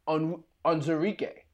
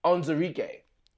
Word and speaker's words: Onwuzurike: Levi Onwuzurik. – Onwuzurike, I believe it is what it is. I got the pronunciations in front of me, so it's Onzurike.